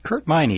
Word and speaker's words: Kurt Meine: Kurt Meine, yes.